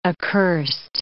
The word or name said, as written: accursed